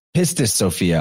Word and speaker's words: Pistis Sophia: So you have the idea of, Pistis Sophia, I'm sorry, not Pisces. Pistis Sophia, but that's very phonetically close to Pisces or Pisces.